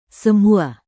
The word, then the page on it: semua: indonesian